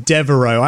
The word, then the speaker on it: Devereux: I looked up the pronunciation. – I thought it was David. – No, Devereaux. I actually looked up the pronunciation on YouTube.